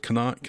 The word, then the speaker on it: Qaanaaq: And the most northerly town in the world is like, in Greenland it's called Kanak and it's spelled Q-A-A-N-A-A-Q.